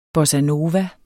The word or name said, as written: nova